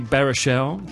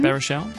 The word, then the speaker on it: Baruchel: Meanwhile, back at the multiplex, Seth Rogen, James Franco and Jay Baruchel, that's how you pronounce his name, Baruchel, play themselves at the end of the world in This Is The End.